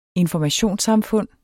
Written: informationssamfund

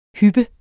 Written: hyp

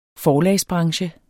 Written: forlagsbranche

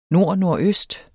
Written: nordnordøst

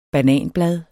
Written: bananblad

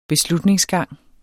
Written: beslutningsgang

